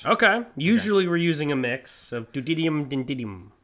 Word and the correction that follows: tritium: Yes, deuterium and tritium.